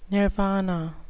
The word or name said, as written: nirvana